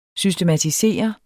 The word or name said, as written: systematisere